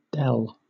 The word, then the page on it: dell: British English